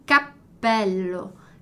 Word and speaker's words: cappello: Capello e cappello. Cappello. Aqui mudou, o P ficou mais alongadinho, mais demorado ali, fiz aquela pausa, né?